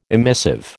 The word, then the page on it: emissive: American English